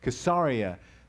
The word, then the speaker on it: Caesarea: It's in a very luxurious section of Israel in Caesarea, Caesarea is how it's said in Hebrew, and they rent this facility there with the purpose of wining and dining and loving on Holocaust survivors.